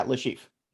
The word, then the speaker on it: Le Chiffre: We cut to a um a scene of uh I think this is where we meet uh, the sheep, right? That's how you say it. – Uh, yeah, Le Chiffre.